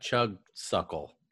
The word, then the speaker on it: Chugsuckle: Chugsuckle.